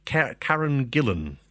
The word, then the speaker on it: Karen Gillan: Starring The Rock, Dwayne Johnson, the girl from Doctor Who, Karen Gillan.